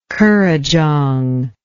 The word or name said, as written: currajong